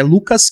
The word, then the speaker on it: Lukaz: E tanto no Facebook, a minha página é Lucas Kim. Meu nome se escreve L-U-K-A-Z Kim.